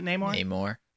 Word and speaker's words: Namor: There was another UFC fighter that was actually up for the main role of Namor. I'll keep butchering his name. Is it Namor? – Namor. – Okay.